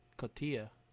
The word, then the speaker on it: cotija: Oh, is it cojita? Is it like, I thought it was cotija. – I'm pretty sure there's a J in there, which is pronounced with an H, I think.